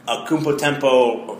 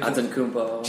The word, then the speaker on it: Antetokounmpo: Akumpo Tempo, did I say it right? – You did not. – Akuta Tempo. – I could Antetokounmpo.